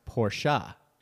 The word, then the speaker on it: Porsche: I cringed every time he said Porsche. It is a German name. And the E at the end is not silent. It is pronounced Porsche.